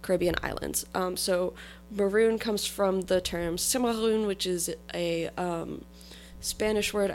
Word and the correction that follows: Cimarron: I pronounced that wrong. I pronounced it like it was French. Anyway, Cimarron, which is a term for livestock that has gone feral.